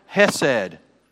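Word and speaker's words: Chesed (חסד): The favorite Hebrew word of Houston Lake Presbyterian Church is chesed, right? It's got to start in the back of the throat to say it right.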